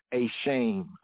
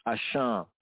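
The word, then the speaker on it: a sham (אשם): Or if you read it in the English way, a shame, a sham, but more correctly pronounced, a sham, the guilt or the trespass offering, right?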